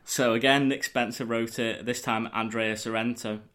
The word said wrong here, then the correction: Sorrentino: Andrea Sorrentino.